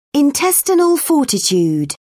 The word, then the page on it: intestinal fortitude: British English